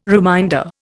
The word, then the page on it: reminder: Indian